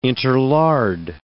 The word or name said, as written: interlard